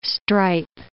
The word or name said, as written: stripe